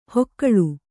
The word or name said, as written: ಹೊಕ್ಕಳು